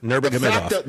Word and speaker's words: Nurmagomedov: Nurmagomedov.